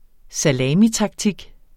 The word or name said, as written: salamitaktik